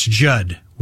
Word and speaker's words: Judd: That's Judd with two D's. The second D is silent.